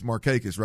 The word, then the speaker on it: Markakis: I know it's a surprise to you, but I butchered that damn last name right there. – We've all been there. – After I said it three times, I said, B-Mac, it's Markakis, right? Yeah.